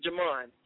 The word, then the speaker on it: Jemone: My name is spelled J-E-M-O-N-E Durant. It should pop up. And on Twitter, at Jamon, J-E-M-O-N-E.